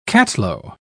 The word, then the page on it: catalo: British English